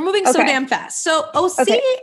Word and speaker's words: OSEA: Yes, the OC body oil. This is OC. It's spelled OSEA and it's the Undaria algae body oil.